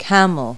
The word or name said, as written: camel